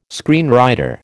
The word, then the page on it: screenwriter: American English